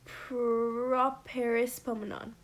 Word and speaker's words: properispomenon: It's extremely obscure, but we were learning about it the other day in Greek, so I wrote it down, and it is properispomenon. Properispomenon. Yes. Is that right? – Yeah.